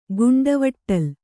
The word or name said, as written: ಗುಂಡವಟ್ಟಲ್